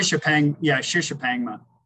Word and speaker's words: Shishapangma: It has to be, because your first one, it was Shishapangma. Is that the correct pronunciation? – Shishapangma.